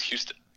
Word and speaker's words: Houston: Yeah, if you ever hear someone say Houston Street, it's Houston.